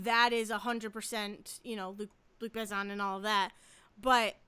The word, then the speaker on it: Luc Besson: But Luc Besson, right? Is that how you pronounce it? – Yes.